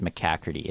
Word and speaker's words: McCackerty: It's pronounced McCackerty. It's Irish.